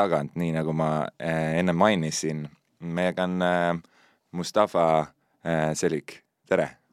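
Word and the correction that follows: Çelik: kas ma hääldasin su perekonnanime õigesti? – jah, enam-vähem, Tšelik. Tšelik? – jah okay., õige, see tähendab, et teras eesti keeles.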